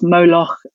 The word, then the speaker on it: Moloch: which within ancient Greek translations of the text have tended to be pronounced Moloch. In the Masoretic text, it's often pronounced Molech.